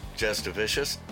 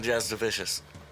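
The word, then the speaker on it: Jasudavicius: Jastavicious? – That is not how I was fucking saying it. – That's exactly how it's pronounced, dude. – Yeah, not how I was saying it. – Yeah, it's Jasmine Jastavicious.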